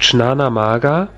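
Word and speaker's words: Jnana-Marga: Da spricht man also so, wie ich es da hinten geschrieben habe, Chnanamaga.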